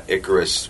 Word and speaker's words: Icarus: Did I pronounce it right that time? – You did. I was like, what are you doing? – I should pronounce it like Ike Turner. – Icarus. Icarus.